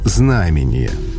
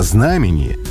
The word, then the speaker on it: знамение: А вот с ударением намного сложнее. Например, большой толковый словарь русского языка ставит ударение на второй слог, считая ударение на первом устаревшим. А как, например, у Ожегова или в словаре русского словесного ударения и большинстве других авторитетных источников. Все они рекомендуют говорить «знамение». На «знамение» настаивает и словарь трудностей произношения и ударения в современном русском языке.